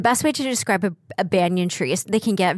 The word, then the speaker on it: banyan: Oh, a banyan tree. – Banyan. – Yeah. – Is that how you pronounce it? Banyan? – B-A-Y-A-N. – B-A-N-Y-A-N? – Yeah, yeah, yeah. – Yeah, yeah. Banyan.